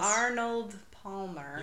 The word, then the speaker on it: Arnold Palmer: Arnold Palmer. – You got it right every time except the first one when you tried to call him an Arnold and like an almond Palmer.